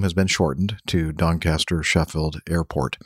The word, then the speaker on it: Doncaster: Robin Hood Airport was actually Doncaster Sheffield Robin Hood Airport, and was, did I say that right? Doncaster? – Doncaster. – Doncaster. Yep.